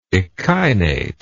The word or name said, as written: echinate